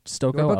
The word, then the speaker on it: Stokoe: James Stokoe.